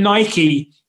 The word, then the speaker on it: Nike: It's like when, just quickly going, when Nike, right, and I'm pronouncing it in the American way, the correct way, I guess, Nike showed solidarity with Colin Kaepernick when he took the knee, right?